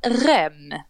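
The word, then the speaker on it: Rennes: Men han, den unga killen från, vad säger man, Rem, det är så man uttalar då, ja.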